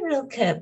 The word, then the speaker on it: Rilke: But I say Rilke, yeah.